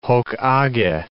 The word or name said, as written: hoc age